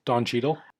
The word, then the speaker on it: Cheadle: Cheadle? – Don Cheadle.